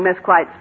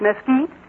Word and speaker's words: Mesquite: And it isn't Mesquite Springs, it's Mesquite.